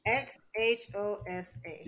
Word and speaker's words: Xhosa: It's spelled X H O S A, and the X you X H O S A. – Yeah, so the X and H sound is a click, so it's Xhosa.